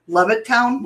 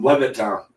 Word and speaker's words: Levittown: Did I say that wrong? – Yes, you did. – Okay, what is it? – Levitt. – Levittown? – Levittown. – Okay. – Levittown, PA.